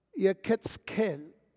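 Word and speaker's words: Yechezkel: Now, the name Ezekiel and the Hebrew pronunciation is Yeketzkel. Yeketzkel.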